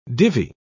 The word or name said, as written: divi